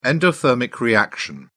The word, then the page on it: endothermic reaction: British English